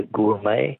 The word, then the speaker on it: gourmet: Words that end with a silent T. Beret is B-E-R-E-T. Which word for a connoisseur comes from an old French word meaning wine taster? – Be a gourmet. – Gourmet, yes. Correct, G-O-U-R-M-E-T.